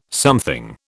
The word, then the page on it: something: British English